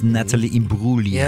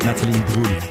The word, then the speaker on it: Imbruglia: Natalie Imbruglia. Bin ich schon mal korrigiert worden? Nicht Imbruglia sagen, sondern es ist Natalie Imbruglia. – Ja, schön weich. – Schön weich. Das G spricht man nicht. Natalie Imbruglia.